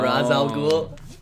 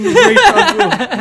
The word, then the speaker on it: Ra's al Ghul: Ra's al Ghul. – That's how you pronounce it. Fucking Ra's al Ghul.